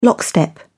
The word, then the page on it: lockstep: British English